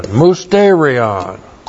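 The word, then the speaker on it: mysterion (μυστήριον): Looks like that in the Greek, M-U-S-T, long E-R-I-O-N, mysterion.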